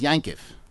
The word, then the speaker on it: Yaakov: If anybody's here from Eastern Europe, you know that the name Yaakov is pronounced Yankiv.